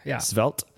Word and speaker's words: Svelte: Svelte.